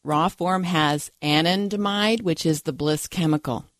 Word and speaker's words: anandamide: We've already gotten to I had to figure out how to pronounce it, but it's anandamide.